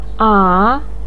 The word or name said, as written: a3